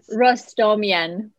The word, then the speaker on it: Rostamyan: Pronounce your last name for me, please. – Rostamyan. No worries.